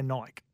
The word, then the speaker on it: Nike: It's is it, it Nike or Nike? – Well, I'm, i say Nike. – I say because it's spelt like bike.